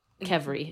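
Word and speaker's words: qvevri: Um, Kevri. Mm-hmm. Just Kevri? Get, get rid of the first V, Kevri.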